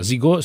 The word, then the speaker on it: Zego: Allora, Zigo si scrive Zego. – Zego.